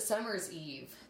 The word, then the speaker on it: Summer's Eve: always a necessity to bring your summer eves. I'm saying that wrong. – Summer eves. – Or is it summer's eve? – Summer's eve. – Right. Yeah, whatever.